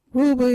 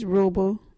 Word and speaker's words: Ruubu: Ruabu is a word for rope. You can use Ruabu in this sentence. Yeah, get that Ruabu. Ruabu is spelt R-U-U-B-U.